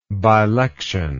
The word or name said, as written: bilection